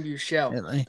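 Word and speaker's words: Buechele: They have, as it stands right now, Blaine Gabbert and Shane Buchelli. I don't know if that's how you pronounce it. Bouchelle. – Shane Bouchelle, the former Texas quarterback.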